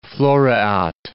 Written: floreat

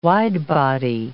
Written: wide-body

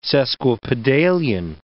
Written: sesquipedalian

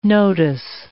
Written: notice

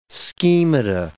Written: schemata

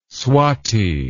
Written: swati

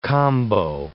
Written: combo

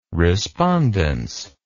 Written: respondence

